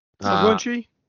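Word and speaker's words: McGlinchey: like combo ta- with Trent Williams and McGlitchinary. Is that how you say it? – McGlitchy. – Uh, McGlitchy on the uh-uh.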